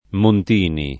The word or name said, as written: Montini